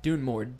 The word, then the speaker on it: Dunmord: Hang on, one more time. Dunmore? – Dunmord. D-U-N-M-O-R-D. Dunmord. – Dunmord. – Cal Dunmord is the brother of the previous king, Vilkas Dunmord IV, who was assassinated.